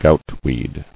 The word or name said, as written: Goutwort